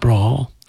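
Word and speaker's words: bransle: It's B-R-A-N-S-L-E, but it's pronounced brawl.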